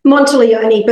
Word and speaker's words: Monteleone: Monteleone, but close enough. – Monteleone. Okay, more Italian sounding.